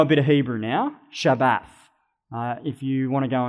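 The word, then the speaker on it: Shabbat (שבת): And the verb in Hebrew is pronounced Shabbat. So you can hear that that sounds like Sabbath.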